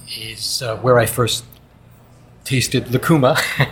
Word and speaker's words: Lacuma: Now, way back in 2001, you founded an operation called Lacuma Films. Did I say it properly? – You said it properly. Lacuma is a Peruvian fruit.